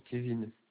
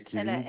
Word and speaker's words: Kevin: Furlan Kevin. – Alors, ça s'écrit comment, s'il vous plaît? – F-U-R-L-A-N. Mmh. Et Kevin K-E-V-I-N. – Comment? – Et Kevin K-E-V-I-N.